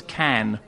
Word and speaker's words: Cannes: And incidentally, it's Cannes. – I'm from Calm. America. What do I know? – Well, you still pronounce it like the French do.